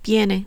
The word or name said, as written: viene